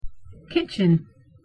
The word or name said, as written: kitchen